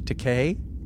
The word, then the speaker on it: Takei: And George Takei.